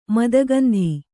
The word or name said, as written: ಮದಗಂಧಿ